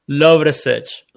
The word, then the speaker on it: Lovrecich: Lovrecich.